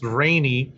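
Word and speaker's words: Rahne: And it's spelled Rahneny. So for the rest of us who didn't realize that it was pronounced Rainy, right? – I read that as Ronnie. Ronnie?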